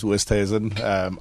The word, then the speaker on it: Oosthuisen: My last name's Oosthuisen.